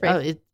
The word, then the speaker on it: Ralph: Ralph. But his name is Rafe. R A like Rafe.